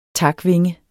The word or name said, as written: takvinge